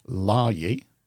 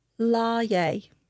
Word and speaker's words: Laryea: And Inigo's assistant, Johnny, played by Wayne Lahey, that's pronounced wrong. I pronounce names wrong. – Do you want me to look? – He took over. – Let me look. – L-A-R-Y-E-A. – Lahey.